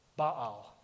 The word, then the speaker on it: Baal: And instead, who he worshipped was this little g god named Baal, actually, is how you say it.